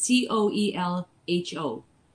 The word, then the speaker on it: Coelho: I, um, I don't know if I say his name right. Paul is C O E L H O. – Yes, Paulo Coelho.